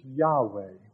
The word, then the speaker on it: Yahweh: This new name for God in Hebrew is represented by four consonants, Y, W, YH, WH, and is often pronounced in English as Yahweh, YHWH.